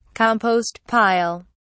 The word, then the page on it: compost pile: British English